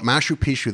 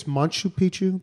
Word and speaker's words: Machu Picchu: What is so freaking great about Machu Picchu that it's on everybody's bucket list? – I think it's Machu Picchu, by the way, if you're going to pronounce it.